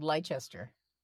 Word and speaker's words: Leicester: Yeah, it's near Leicester, which is spelled Leicester. Leicester. It's in the middle of the country.